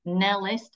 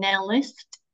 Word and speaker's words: Nellist: I'm just Glenys Nellist, and it's a very honestly, I think I'm the only Glenys Nellist in the whole wide world.